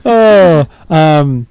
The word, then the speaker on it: Pizzolatto: That's how he says, hi, you're talking to Nick Pizzolatto. Pizzolatto.